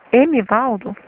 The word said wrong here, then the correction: Edivaldo: Edivaldo. – O D não é mudo, não, né? – É D-I. Edivaldo Araújo Ponte.